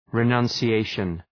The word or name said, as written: renunciation